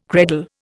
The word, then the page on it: credal: Indian